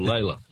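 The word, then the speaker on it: Lalor: Oh, Lawler. Or Layla. Lawler's how it's supposed to be pronounced, but Layla's the other way.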